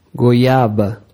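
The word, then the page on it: goiaba: Brazilian Portuguese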